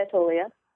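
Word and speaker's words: Attolia: Atolia accented on the second syllable? – Yes, I say Atolia.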